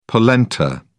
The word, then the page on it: polenta: British English